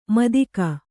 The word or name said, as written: ಮುತ್ತಗ